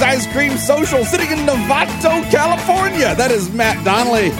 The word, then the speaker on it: Novato: Uh, I know I'm overpronouncing it. You just pronounce it casually Novato. Uh, right. But when you say it too quickly, people think you're saying Nevada.